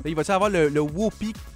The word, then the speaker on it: Whoopie: Ben, ça ressemble à Whoopie Goldberg, ça s'écrit W-H-O-O-P-I-E. Donc, comme groupie, mais Whoopie.